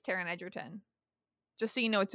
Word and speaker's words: Taron Egerton: I can tell you it's Taron Egerton.